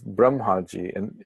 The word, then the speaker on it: Brahmaji: There should be a long A, but Brahma is Parabrahma, which is Atma, just to clarify. So this is Prajapatihi. Is Brahmaji, and he is the devata for the procreative capacities.